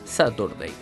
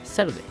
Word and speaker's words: Saturday: No es Saturday, es Saturday. Bien.